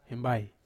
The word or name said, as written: hembai